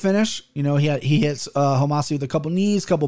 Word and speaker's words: Homasi: Hit Sabah with some Homasi, is how you say his name.